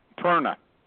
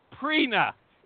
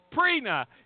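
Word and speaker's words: Prerna: Perna. – Perna? – Perna. – No, it's Prina. It's Prina. It's a silent R.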